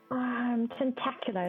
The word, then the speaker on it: Tentacula: Is it Tentacula or Tentacula? How do you want to be pronounced? – Tentacula is fine. – Tentacula. – Like spectacular.